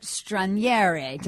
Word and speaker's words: Straniere: Robert Stranieri. Did I pronounce that with enough of an Italian, Joni Italian accent? – Yes.